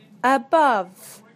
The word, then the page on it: above: British English